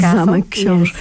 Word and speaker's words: Książ: Ksiąg Castle? Ksiąg Castle? – Zamek Książ.